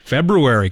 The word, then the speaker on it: February: February. Got to pronounce the R.